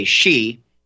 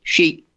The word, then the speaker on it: Xi: And interestingly, there's a specific Chinese pronunciation of the name Xi, which is English and American will say she. There is a more specifically Chinese. Let me try to imitate it. Something like, she.